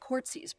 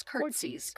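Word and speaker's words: court'sies: It's spelled courtsies, but it's curtsies.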